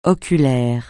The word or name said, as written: oculaire